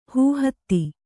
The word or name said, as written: ಹೂಹತ್ತಿ